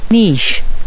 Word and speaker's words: niche: Okay, I feel so incredibly vindicated, because going to Merriam-Webster Dictionary here, online, the first pronunciation available is... – niche. – You see, that's the way it should be pronounced. Then the second pronunciation available is... – niche. – So, there you go. It's niche. It's a niche market. N-I-C-H-E,